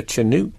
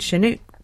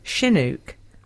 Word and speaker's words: Chinook: But I have to say, I twitch every time I hear the word Chinook pronounced with a hard ch sound. I've only ever heard it pronounced sounding more like Chinook. Second syllable rhymes with book.